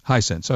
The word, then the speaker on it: Hisense: Hisense.